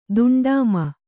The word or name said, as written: ದುಂಡಾಮ